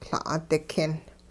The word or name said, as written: La'-dikin